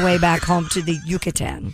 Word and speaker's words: Yucatan: No, you don't have to say it like that. Yucatan is fine.